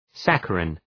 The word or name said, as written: saccharin